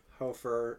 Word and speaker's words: Hofer: Hofer is how it's pronounced, although Hofer.